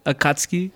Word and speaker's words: Akatsuki: Akatsuki.